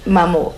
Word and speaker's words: maman: Because it's spelled M-A-M-A-N. I would have said maman. – Oh, yeah. Well, it's French. – Yeah, so, but it's... – Maman. – Maman.